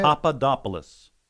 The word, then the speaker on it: Papadopoulos: Papadopoulos.